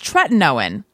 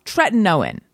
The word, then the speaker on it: tretinoin: However, I have recently learned it's tretinoin, and no one mentioned this to me. So I'm going to assume everyone else believes that much in my pronunciation, or they all drop the ball. You guys, I've been saying it wrong for a long time now. Tretinoin. Let every syllable have its moment.